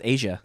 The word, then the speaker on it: Aja: How do you say that? AJA. I don't even know how to pronounce it. – Oh, it's just Asia.